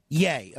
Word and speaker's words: Ye: Is the proper pronunciation of his new name Ye or Yay? – It's Yay. – Yay.